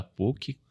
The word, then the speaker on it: PUC: Minha formação é administração, sou um verdadeiro filho da PUC, Atenção Olha a pronúncia, PUC.